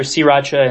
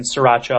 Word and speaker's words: sriracha: Am I saying it right, by the way? – Absolutely. I think I hear sriracha and sriracha a lot, and I think both those are just perfectly acceptable.